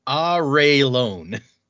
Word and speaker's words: Arelon: And the country that they're in, is it Arlon or Arlon or? – I always said Arlon. According to this, it is pronounced Arelon.